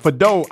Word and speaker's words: Fadeau: Join 92.9 The Game this Saturday starting at noon at Fadeau Irish Pub in Buckhead for the Atlanta United versus New York FC watch party. Did I say it right, Daryl? Fadeau. Yay!